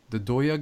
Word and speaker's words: Doya: For example, let's talk about the Doya guy. Is that how you say it? – Yep.